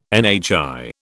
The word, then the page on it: NHI: British English